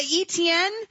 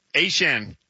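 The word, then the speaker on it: Achane: And so apparently, A-Chan, who was the artist formerly known as ETN, says that it's always actually been A-Chan, but we've always been pronouncing it wrong with ETN.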